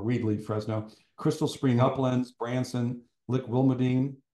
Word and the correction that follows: Lick Wilmerding: Am I saying that right? Lick Wilmerdine. – Lick Wilmerding. Yeah, it's Okay.